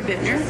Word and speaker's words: Bittner: It's Barbara Bittner, and it's spelled B-A-R-B-A-R-A, and my last name is B-I-T-T-N-E-R.